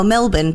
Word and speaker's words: Melbourne: Well, Melbourne, but we don't pronounce our R's.